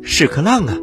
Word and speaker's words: 屎壳郎: 小朋友们，屎壳郎啊，正音应该读作。屎壳郎，它也被称呼为枪狼，但是啊，日常口语当中，喜欢管它叫屎壳郎，觉得读这个音特别好笑。